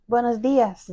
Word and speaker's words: Buenos días: How do you say it in Spanish? – Buenos días. – Buenos dias.